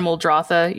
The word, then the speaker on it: Muldrotha: Which is Muldrotha. – I think that's right. – I think is how that's pronounced. – Yeah, I would say Muldrotha or Muldrotha, either way.